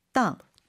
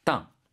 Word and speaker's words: 当: 땅. – 땅. 발음 유의해야 되죠. 사성입니다. 땅.